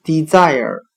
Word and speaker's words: desire: 我们用谐音法来记忆这个单词 desire，它的发音很像汉语的滴在耳，滴水滴的滴，在再次的在，儿是儿化音。我们这样来联想这个单词，在一个干旱的地区，人们是多么希望老天爷能够再多下几滴雨呀！今天所学的单词 desire，